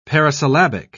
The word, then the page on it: parisyllabic: British English